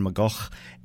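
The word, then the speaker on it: McGeoch: Magazine featuring singer Howard Devoto and guitarist John McGough. I think that's the Scottish pronunciation. I went through that with our music director, Connor, earlier, and we've decided it is John McGough.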